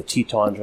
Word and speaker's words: Tetons: And the Tetons are in the background.